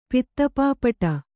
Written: ಪಿತ್ತಪಾಪಟ

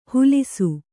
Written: ಹುಲಿಸು

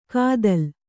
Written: ಕಾದಲ್

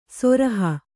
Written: ಸುರುಹು

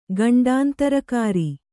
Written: ಗಂಡಾಂತರಕಾರಿ

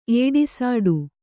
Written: ಏಡಿಸಾಡು

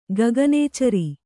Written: ಗಗನಚರ

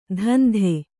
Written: ಧಂಧೆ

